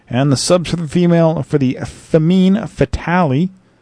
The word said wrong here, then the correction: Femme Fatale: Femme Fatale, that's what I say. I can pronounce that a little bit easier.